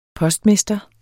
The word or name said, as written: postmester